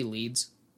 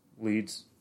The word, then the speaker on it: Leeds: mostly identified as Deborah Leds. – Wouldn't it be Leeds? – Leeds, yeah. I've always pronounced it Leds.